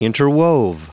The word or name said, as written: interwove